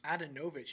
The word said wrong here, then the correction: Handanovic: You mean Handanovic? – Yeah, whatever you call him.